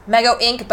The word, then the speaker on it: Mego Inc: Mego Inc., but INC, not INK.